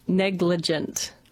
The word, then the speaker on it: negligent: As you would have heard me say it incorrectly about 2 minutes ago. It is negligent.